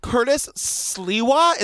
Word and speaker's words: Curtis Sliwa: Curtis Sliwa.